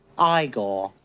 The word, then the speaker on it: Igor: You must be Igor. No, it's pronounced Igor.